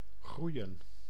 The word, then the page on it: groeien: /ˈɣrujə(n)/